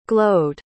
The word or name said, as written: glowed